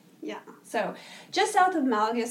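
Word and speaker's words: Málaga: But we kept saying Malaga. – Yeah. – But it's pronounced Malaga. – That is the correct way to say it.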